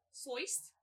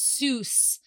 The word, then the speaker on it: Seuss: Soist? – Soist. So, the Anglicized... – Soist. – The Anglicized pronunciation is Seuss. But it is incorrect.